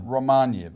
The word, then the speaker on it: Romaniw: So the name of the person, the correct pronunciation is Stefan Romaniov. – Romaniev. – Stefan Romaniev, R-O-M-A-N-I-W, who is the co-chair of the organisation of, Federation of Ukrainian Organisations.